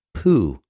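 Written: οὐ

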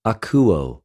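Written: ἀκουω

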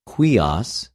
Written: υἱος

